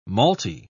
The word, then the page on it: malty: British English